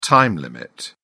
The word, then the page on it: time limit: British English